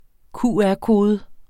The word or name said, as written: QR-kode